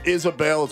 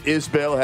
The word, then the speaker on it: Isbel: Kyle, you call him Isabel. It's pronounced Isbel.